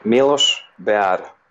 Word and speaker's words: Milos Beara: It's Milos Beara.